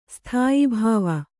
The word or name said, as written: ಸ್ಥಾಯಿಭಾವ